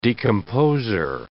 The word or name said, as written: decomposer